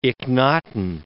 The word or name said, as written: Ikhnaton